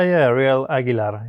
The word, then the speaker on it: Ariel Aguilar: Yeah, Ariel Aguilar.